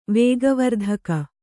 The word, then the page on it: ವೇಗವರ್ಧಕ: vēga vardhaka